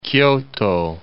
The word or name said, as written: Kyoto